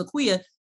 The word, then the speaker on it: Akua: I never had to, nobody asked that, you know, when I said my name is Akua, they knew exactly how to say it, they knew how to pronounce it.